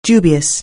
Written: dubious

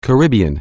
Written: Caribbean

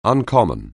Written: uncommon

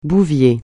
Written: bouvier

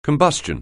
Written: combustion

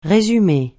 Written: résumer

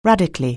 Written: radically

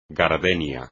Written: gardenia

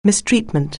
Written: mistreatment

